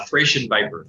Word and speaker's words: Thracian Viper: And if you could maybe just speak briefly to them as well, which was Blue Bridge and Thracian Viper or Thracian Viper. I'm not sure how to pronounce that. But yeah, Thracian Viper.